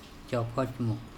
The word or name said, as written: cOkhocmo